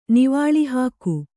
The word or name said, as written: ನಿವಾಳಿಹಾಕು